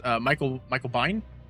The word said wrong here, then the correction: Biehn: Michael Michael Biehn from Aliens.